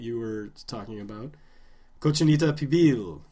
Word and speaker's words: pibil: But, well, you said it correctly. Pibil.